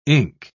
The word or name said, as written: ink